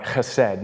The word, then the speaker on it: chesed: And the word is chesed. You really got to get that phlegm in there to get it right, chesed.